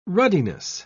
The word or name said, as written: ruddiness